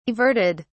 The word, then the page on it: averted: British English